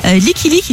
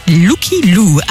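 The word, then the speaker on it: Lykke Li: 22h29, l'heure de retrouver notre album de la semaine, il est signé Licky Lick, il faut paraît-il prononcer Looky Lou.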